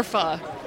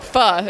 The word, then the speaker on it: pho: Or pho. – Or what? – Pho. It's a Vietnamese soup. – We pronounce it pho. – Most Americans call it pho.